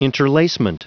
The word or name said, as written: interlacement